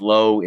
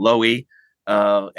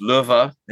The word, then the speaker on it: Lowe: The fun thing about my role being global or international often is my name is pronounced, my surname is pronounced differently everywhere you go. In the United States, it's Lowe, and my family decided to call it Lowe, and in Germany, it's Löwe, and in Spain, it's Löwe, and in England, it can be just about anything.